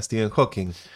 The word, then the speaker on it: Stephen Hawking: Cuenta la leyenda que conociste también a Stephen Hawking. – Efectivamente, y además lo has pronunciado maravillosamente bien. Creo que soy la primera persona que escucho que pronuncia bien el nombre y apellido.